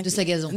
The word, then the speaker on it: Sagazan: Je vais écorcher son nom, donc je veux bien... – De Sagazan. – De Sagazan.